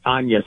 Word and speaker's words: Agnes: spelled like Agnes, but Agnes.